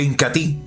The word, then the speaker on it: Incatea: Se escribe Incatea, pero se pronuncia Incatí.